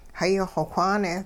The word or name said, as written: hayi-xw whaane